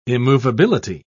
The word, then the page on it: immovability: British English